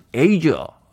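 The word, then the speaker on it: Asia: 아시아라고 우리는 당연히 알았는데 외국 분들은 에이저 이렇게 발음을 하더군요. 에이저입니다.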